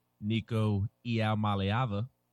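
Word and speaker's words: Nico Iamaleava: It doesn't mean that Tennessee is going to land Nico Iaomaliava.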